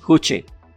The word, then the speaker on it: Juche: Y es la filosofía Juche, creo que se pronuncia. Se escribe Juche. – Sí, Juche.